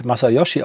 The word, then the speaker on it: Masayoshi: Ähm, sein Vorname wird korrekt Masayoshi ausgesprochen, aber er lässt sich häufig Massa nennen weil das auch Amerikaner, Ausländer allgemein besser aussprechen können.